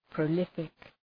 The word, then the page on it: prolific: prə’lıfık